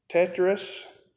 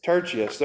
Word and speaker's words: Tertius: We also know that it was penned by Tertius. I'm sure I didn't say that right. Tertius, there you go.